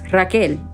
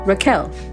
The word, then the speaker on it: Rachel: It is spelled that way. However, it is pronounced Raquel or Raquel. And she says, looks me dead in the eye and says, no, it's not.